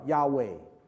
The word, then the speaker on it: Yahweh: 这个希伯来文没有母音的，它只有子音，是四个子音 YHWH，那么它发音叫 Yahweh，我们中文翻译叫耶和华。